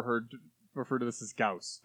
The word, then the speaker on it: Gauss: Wait, isn't that Gauss? – Yes, that's typically how you pronounce it. – Like, as in the guy's last name? – Johann Gauss? – Yeah.